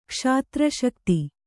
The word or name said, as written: ಕ್ಷಾತ್ರಶಕ್ತಿ